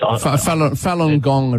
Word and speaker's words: Falun Gong: There's about 60-odd people down there at the moment, holding banners saying stop the persecution of Falun Guan, I think it's pronounced. – Falun Gong, it'll be.